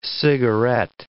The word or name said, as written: cigaret